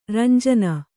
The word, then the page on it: ರಂಜಾನ್: Kannada